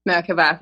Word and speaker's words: Merkaba: So as an example, when you do work with your natural geometry, that's all you are anyways, is a Merkaba. Did I say it right? – Merkaba, thank you.